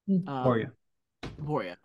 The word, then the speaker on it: Topuria: Volkanovski versus Topuria. Or Topura. I can't know how it's pronounced. – Topuria.